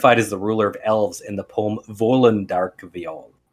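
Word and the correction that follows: Völundarkviða: No, I said that wrong. Volundarkvitha.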